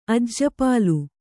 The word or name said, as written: ಅಜ್ಜಪಾಲು